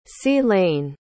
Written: sea-lane

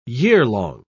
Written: yearlong

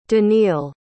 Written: danele

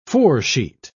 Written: foresheet

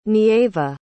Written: Nieva

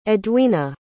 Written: edwina

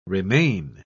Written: remain

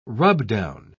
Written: rubdown

